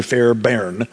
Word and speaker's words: Fairbairn: F-A-I-R-B-A-I-R-N, looks like it should be Fairbairn, but I think it's pronounced Fairbairn, Donald Fairbairn, has a book called Life in the Trinity.